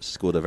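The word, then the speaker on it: Škoda: Yes, thank you very much indeed to Skoda, as we determined last night was the correct pronunciation.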